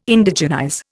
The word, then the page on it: Indigenize: Indian